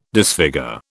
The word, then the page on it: disfigure: British English